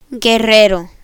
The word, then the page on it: guerrero: spanish